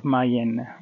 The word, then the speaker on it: Mallén: Let's see what uh, a little overview of uh, town of Mallén with an accent on the he.